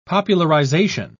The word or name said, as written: popularization